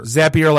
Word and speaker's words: Zapier: Zapier like happier.